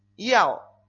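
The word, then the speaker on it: Yao (要): Entonces, la Y en chino se pronuncia como nuestra I, más o menos. Ahora vemos las diferencias. Y la A y la O no nos cambian. Entonces, en el cuarto tono es Yao.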